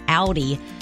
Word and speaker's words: Audi: Audi. Did I say that right? – Yeah. – Audi SUV.